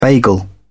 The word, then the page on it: bagel: British English